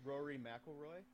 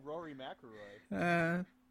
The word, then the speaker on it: Rory McIlroy: Rory McIlroy? – That's it, yeah. – close. You missed some syllables. – I need to my golf. – And, I hate golf, but I know Rory McIlroy.